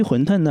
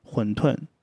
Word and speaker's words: 馄饨: 就是关于馄饨呢，我第一个是想说一下它的读音。南方人多数是读馄饨啊，轻声，配音里面也是馄饨，对。但是我身边有一些北方朋友，他们经常会读成馄饨，馄饨，他们会发成登温敦的音。